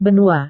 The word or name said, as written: benua